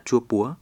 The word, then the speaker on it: Chua Púa: Pu Cao theo tiếng Mông đọc là Chua Púa, nghĩa là núi quây.